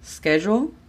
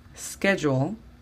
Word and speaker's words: schedule: La pronunciación de la S debe de comenzar desde la S. Schedule. Schedule, no schedule.